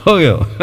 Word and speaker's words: Vogel: É a pronúncia é assim, mais ou menos? – É isso mesmo. – Fogel. – É, Fogel. Muita gente fala, o sobrenome, para você escrever, ele é Vogel.